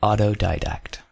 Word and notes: autodidact: American English

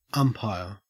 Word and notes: umpire: Australian English

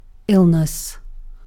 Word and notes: illness: British English

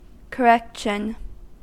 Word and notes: correction: American English

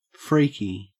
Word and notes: freaky: Australian English

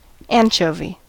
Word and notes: anchovy: American English

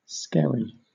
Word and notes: skerry: British English

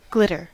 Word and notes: glitter: American English

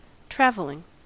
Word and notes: travelling: American English

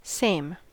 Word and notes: same: American English

